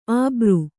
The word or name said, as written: ಅಬರು